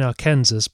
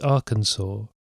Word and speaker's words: Arkansas: I keep saying Arkansas, but apparently it's Arkansas.